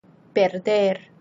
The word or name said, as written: perder